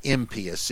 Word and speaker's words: impious: But impious is the way it was supposed to be pronounced for a long time.